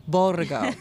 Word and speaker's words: Borgo: Borgo.